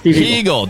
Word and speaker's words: Hiegel: Hegel, just like Eagle, but Steve Hegel.